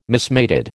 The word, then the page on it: mismated: American English